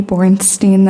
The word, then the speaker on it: Bornstein: My name is Maggie Bornstein. That's spelled B-O-R-N-S-T-E-I-N, here on behalf of the ACLU of Montana.